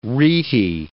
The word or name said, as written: rete